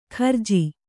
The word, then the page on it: ಖರ್ಜಿ: Kannada